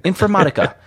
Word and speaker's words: Informatica: Am I pronouncing that right? – Yeah, just like the Spanish, Informatica. – Informatica.